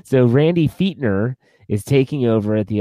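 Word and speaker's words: Fichtner: His name is pronounced Fietner. – Yep, Fietner. – I thought it was Fitchner.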